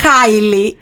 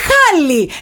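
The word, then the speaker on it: Halle: Μα δεν είναι Χάιλι, είναι Χάλι. Εγώ φταίω που είναι Χάλι. – Όχι, ρε, εσύ, ναι. Εντάξει, άμα ζούσε Ελλάδα, θα είχε φάει μπούλινγκ. – Αυτό. είναι η Χάλι Μπέρι, κουκλάρα στα 62, τη 62, πόσα κλείνει, 60, τέλος πάντων.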